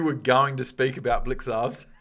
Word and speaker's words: Blicavs: You're like, Blycavs. No, you're like, it's not Blycavs because I never hear BT say, oh, and Blycavs got the ball.